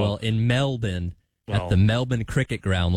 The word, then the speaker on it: Melbourne: Locals do not say Melbourne. So I think they do say Melbourne. – Okay. – So it's kind of like not pronouncing the T's in Atlanta. – Yeah. So the R is silent.